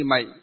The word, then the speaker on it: mệnh: Nó vẫn là một cái chữ Hán ấy, nhưng ở miền Bắc chúng ta đọc là mệnh, còn ở miền Nam đọc là mạng.